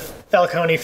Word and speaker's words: Falcone: Um, a lot of name characters you'd recognize, like uh, uh the Falcone family shows up, or Falcone, Falcone, however it's supposed to be pronounced. It's Italian. I always say E at the end because it's Italian.